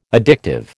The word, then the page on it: addictive: American English